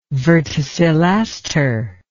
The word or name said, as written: verticillaster